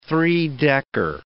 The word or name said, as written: three-decker